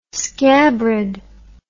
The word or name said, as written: scabrid